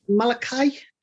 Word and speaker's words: Malachi: Is that how you pronounce it? His AEW name? – Malachi. – Malachi. Malachi. We'll look at me.